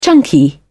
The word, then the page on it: chunky: British English